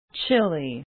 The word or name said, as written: chili